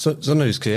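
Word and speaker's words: Sønderjyske: 2017 bis 2020 warst du bei Sonderjyske. Spreche ich es eigentlich richtig aus? – Sonderjyske, ja. Okay. Völlig korrekt. Viele können den Namen nicht aussprechen, aber du machst das sehr gut.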